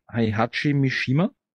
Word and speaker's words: Heihachi Mishima: Und nochmal zurückzukommen zu Bastis Thema, glaube ich, die PS2-Version war, ich werde den Namen fix falsch aussprechen, Heihachi Mishima.